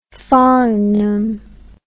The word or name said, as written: fangum